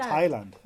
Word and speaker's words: Thailand: I said Thailand, you know, pronouncing my THs. – Yeah, because there's a H in there. How do you say it? Thailand. – Thailand. – Oh, Thailand. Yeah, oh. – Thai food, not Thai food, apparently.